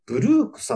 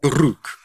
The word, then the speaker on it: BLUEK: この方が、えっ、ー、と、お名前、 BLUEK と書いて、ブルークさんという MC なんですけれども。ブルーク。